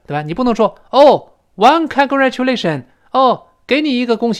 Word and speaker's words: congratulation: 你像这里面 t 后面又碰到了字母 u，它发音也是 u，又是半元音 e 跟 o 到一块儿，所以 t 撞见了 e。变成 ch，再读一遍，congratulation，congratulation，所以 congratulation，congratulation 不要读成 congratulation，不对。